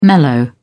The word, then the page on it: mellow: British English